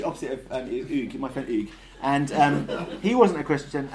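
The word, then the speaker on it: Hugues: which you say the same as Hugh, but in reverse. So all the syllables of Hugh that are silent, you pronounce, and all the ones that are sounded, you silent. So instead of Hugh, it's Oog.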